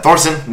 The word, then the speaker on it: Thorson: I got his name, maybe his last name wrong, but Matt Thorpson or Thorson. Anyway, he's Canadian. but it's great. That game debuted, I believe, at Fantastic Fest last year. Matt Thorson. Nailed it.